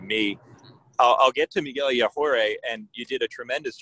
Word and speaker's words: Yajure: we figured we don't know how to say this. So we just kept calling him Mickey Yager, knowing that that was definitely not the pronunciation.